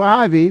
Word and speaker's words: Ivey: I have my special guest with me, Stu Ivey, who's a former chairman. I'm sorry, Ivey. I've been mispronouncing it the whole time.